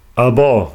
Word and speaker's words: Alban: Äh, wie wurde er ausgesprochen? Alban? – Alban, genau.